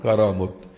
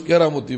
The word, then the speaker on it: কারামত: আর আল্লাহ পাক নবীর সাচ্চা অনুসারী কোন ওলি আল্লাহর হাতে নবীর গোলামীর বরকতে যখন কোন অলৌকিক ঘটনা ঘটান সেটার নাম হল কারামত, যেটাকে মানুষ কেরামতি বলে। সাধারণ মানুষ কেরামতি বলে না, শব্দটা বিশুদ্ধ উচ্চারণ হল কারামত, কেরামত না কারামত।